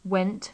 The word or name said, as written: went